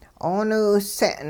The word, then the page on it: 'ooniwse'n: hupa